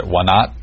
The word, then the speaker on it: Wanat: So this is 2008 now, and you are serving, I'm not sure if I'm saying this right, is it Wanat? – Yep. – Wanat Vehicle Patrol Base.